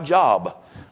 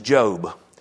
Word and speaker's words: Job: For you kids that are about phonetically spelling things right, it looks like Job, but the man's name is Job.